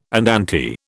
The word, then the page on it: andante: British English